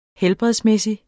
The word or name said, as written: helbredsmæssig